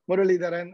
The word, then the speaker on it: Muralidaran: Anand Muralidaran, and I based out of Silicon Valley.